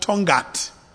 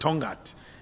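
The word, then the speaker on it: Tongaat: She knows what I'm talking about, because in Natal there's a town called Tongat. But actually the real name was Utongati. And when the colonialists could not pronounce it, they said Tongat.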